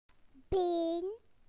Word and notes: leg: British English

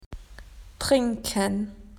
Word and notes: to drink: Australian English

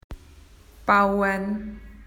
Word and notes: to build: Australian English

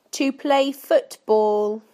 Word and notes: to play football: British English

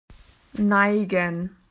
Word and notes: slanted: Australian English

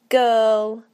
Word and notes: girl: British English

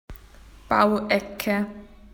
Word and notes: building corner: Australian English

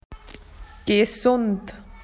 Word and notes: healthy: Australian English

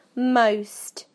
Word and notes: most: British English

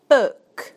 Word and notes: book: British English

